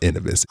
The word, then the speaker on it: Innovis: It is the only site authorized by all four credit bureaus, Equifax, TransUnion, Experian, and the little known Innovis. It is so infrequently referred to that I'm not quite sure that I'm pronouncing it correctly, but I believe it is pronounced Innovis, I-N-N-O-V-I-S.